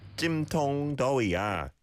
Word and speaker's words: 찜통더위야: 찜통더위야. Good pronunciation. Tong, tong, the O vowel sound. Let's be careful of.